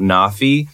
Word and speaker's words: NAAFI: So if you guys haven't checked out Nafi, it's spelled N-A-A-F-I, and it's just an abbreviation.